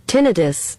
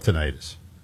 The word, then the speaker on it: tinnitus: Yeah, but the top American pronunciation is... – tinnitus. – Tinnitus. – That is preferred in the medical and scientific community, also highly common in British English.